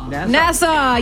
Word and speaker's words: Nassau: Nassau.